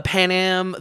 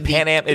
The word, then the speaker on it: Panem: and is it pronounced like the old airline? – Pan no, Am? no. Uh, it's a P A N E M.